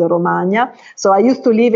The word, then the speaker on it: Romagna: I used to live in Castrocaro, which is, as you pronounce it, as Romania, but it's Romagna, Emilia Romagna.